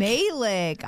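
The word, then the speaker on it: Malek: I believe his name is Rami Malik? – That's really close. See, you pronounce it though. You're doing these like a lot of people do. Rami Malik, as they say. – It's Malik? – Malik.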